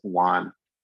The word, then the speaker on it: WON: But most of the time, if they're large, after about 4 weeks, they will organize into what we call walled off necrosis, or WON, which we just pronounce it WON.